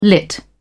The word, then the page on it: lit: British English